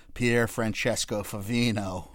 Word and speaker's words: Pier Francesco Favino: Of all the names you've butchered and baffled on this show, and you come in here and nail Pier Francesco Favino like he's one of your brethren.